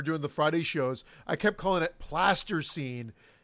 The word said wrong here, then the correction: plasticine: and Eric did enunciate, and I think he was trying to get back and going, I was pronouncing it incorrectly, because it is plasticine.